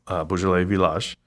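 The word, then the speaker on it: Beaujolais Villages: That's how it's spelled on when you guys look at it. It's going to say Villages, pronounced Beaujolais Village.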